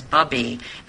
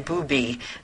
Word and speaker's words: bubby: Sometimes, like, the Jewish word for grandmother is bubby, and she kept saying booby, and I kept saying, no, Gabriella, that's not the right pronunciation.